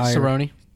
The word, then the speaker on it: Cerrone: Cerrone.